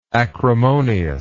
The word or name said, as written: acrimoniously